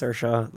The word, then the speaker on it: Saoirse: That's like Saoirse Ronan, like Saoirse. Like, no, it's Saoirse. Like, she's Irish.